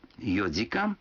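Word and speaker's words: йодикан (四時間): Некоторые цифры в этих случаях произносятся не так, как обычно. 4 часа – это йодикан, 7 часов – ситидикан, а 9 часов – кудикан.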